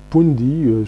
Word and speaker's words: Pundi: c'est Pundi, je crois que ça se prononce comme ça, P-U-N-D-I.